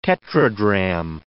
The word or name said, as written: tetradrachm